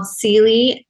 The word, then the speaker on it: Ceally: And she spells it differently, but my name is spelled Ceally, C-E-A-L-L-Y.